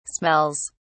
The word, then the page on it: smells: British English